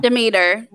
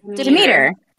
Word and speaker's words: Demeter: For somebody who loves Greek mythology, I don't know how to say this properly. Demeter? Demeter? I don't know. – Demeter. – Demeter. Great.